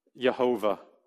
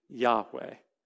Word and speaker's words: Yahweh: If you read the name Yahweh with the vowels of Adonai, you want to know how it's pronounced? Jehovah. Jehovah. That's where the name Jehovah came from. It's technically Yahweh. So anytime you see capital L-O-R-D, all caps, that's not Adonai, Lord.